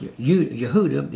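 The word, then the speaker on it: Yehuda (יהודה): He says this, Isaiah was a prophet of Israel to the tribe of Judah in the court of the kings of it's pronounced Yehuda, is Judah. His ministry lasted over 60 years.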